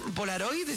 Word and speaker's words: Polaroid: ¿Está bien Polaroid? ¿Se pronuncia también? ¿Está bien pronunciado? Sí.